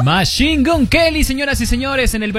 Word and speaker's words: Machine Gun Kelly: Machine Gun Kelly.